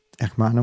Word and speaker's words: Hermana: Hermana Muerte. – Her, Hermana, H se ne izgovara na početku. Hermana Muerte ili Sister Death?